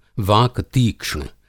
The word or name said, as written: वाक-तीक्ष्ण